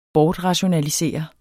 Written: bortrationalisere

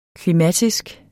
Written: klimatisk

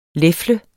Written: lefle

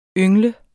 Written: yngle

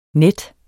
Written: net

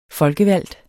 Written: FOLKEVALGTE

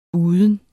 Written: uden

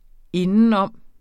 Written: indenom

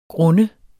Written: grund

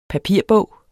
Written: papirbog

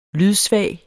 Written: lydsvag

